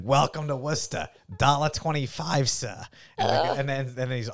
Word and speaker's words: Worcester: and that is located in Worcester, Massachusetts. – Worcester. – I dare anyone who doesn't live in Massachusetts to look up that town and try to say it right. It took me years. Worcester.